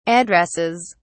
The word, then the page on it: addresses: British English